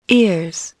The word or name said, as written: ears